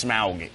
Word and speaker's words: Smaug: No, it's Smaug is the name of the dragon. It's very important to pronounce it correctly.